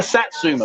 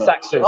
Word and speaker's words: Satsuma: Am I saying it right? A Zatzuma. – You mean a Satsuma? – Satsuma.